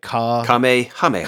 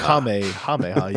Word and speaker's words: Kamehameha: It's like Kamehameha. – Kamehameha, yeah.